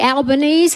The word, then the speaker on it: Albanese: I want to also especially thank Anthony Albanese.